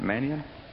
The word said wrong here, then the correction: Menahem: Menahem. – Very good.